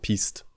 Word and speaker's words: Piste: Ja, ähm, häufig wird das französisch ausgesprochen und dann sagt man nicht Piste, sondern Piste.